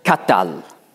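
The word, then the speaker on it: qatal: Im Kal, also im ersten Verbalstamm, heißt das Katal ausgesprochen. AA wird eingesetzt und das heißt, er tötete.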